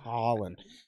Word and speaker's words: Haaland: When you say Haaland, I think the Netherlands. – Haaland? That's the point. That's the name. Haaland. It's not a long A. Haaland. Yeah. It's Haaland.